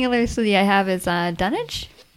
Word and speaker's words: Dunwich: But yeah, so the Dunwich city of Dunwich was a medieval city that was like one of the biggest well, it was the biggest city in East Anglia.